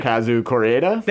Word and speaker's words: Hirokazu Koreeda: Hirokazu Koreeda.